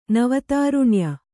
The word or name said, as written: ನವತಾರುಣ್ಯ